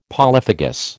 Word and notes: polyphagous: American English

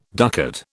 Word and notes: ducat: British English

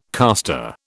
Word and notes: caster: British English

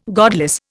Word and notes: godless: Indian

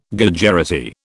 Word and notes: Gujarati: British English

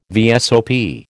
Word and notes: VSOP: American English

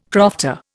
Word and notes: crofter: Indian

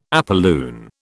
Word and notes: apolune: British English

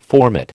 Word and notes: formate: American English

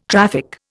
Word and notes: traffic: Indian

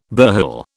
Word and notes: burhel: British English

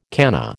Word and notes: canna: American English

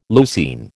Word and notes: leucine: American English

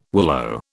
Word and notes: willow: British English

